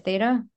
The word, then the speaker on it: theta: Theta? – Theta.